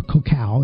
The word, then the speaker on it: cacao: According to researchers at Cornell University, cocoa, or cacao is actually how they say it, is a major source of cancer-fighting antioxidants.